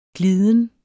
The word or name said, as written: gliden